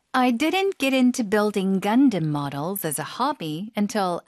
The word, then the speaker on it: Gundam: Gundam. – Gundam. – Gundam. – 앞쪽에 힘주고 뒤쪽에 살짝 약화시켜서. 네. 영어식 발음입니다.